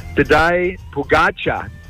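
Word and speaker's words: Tadej Pogačar: Tadej Pogacar.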